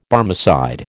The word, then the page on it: Barmecide: American English